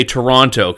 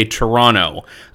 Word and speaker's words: Toronto: Americans say Toronto. Canadians say Toronto, as though there's no second T.